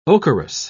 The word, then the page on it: ocherous: British English